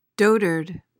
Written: dotard

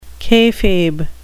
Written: kayfabe

